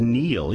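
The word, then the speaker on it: Niall: I think it's pronounced Neil, even though it's spelt Nile. – Okay. – Because he's Irish.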